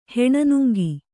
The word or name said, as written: ಹೆಣನುಂಗಿ